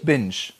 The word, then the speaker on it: binge: Binge, natürlich Binge. Englisch, natürlich ja. Englisch.